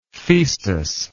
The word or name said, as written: phaestus